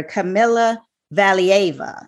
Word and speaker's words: Kamila Valieva: So Vanetta, today we are going to be talking about what everybody's been talking about, and that is the Russian skater, Kamila Valieva.